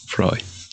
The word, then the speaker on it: Freud: Freud, bye, si era Freud, Freud. Freud. Sí se pronuncia Freud.